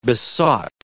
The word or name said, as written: besought